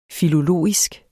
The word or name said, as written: Filologisk